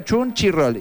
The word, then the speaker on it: Crunchyroll: Pero se pronuncia Crunchyroll. Y después se escribe C-H de vuelta. C-H. I latina. R-O-L-L. Crunchyroll.